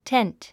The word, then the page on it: tent: tént; テェント